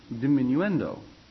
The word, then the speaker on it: diminuendo: D-I-M-I-N-U-E-N-D-O, diminuendo. Oh, diminuendo. Sound it out, spell it. Phonics and all that, you know? One nice thing about Italian, it's spelled just the way it sounds. There's not all the added attraction words like in English, or added attraction letters like in English. It's every vowel sound has a vowel. And every vowel has its own sound.